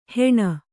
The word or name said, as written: ಹೆಣ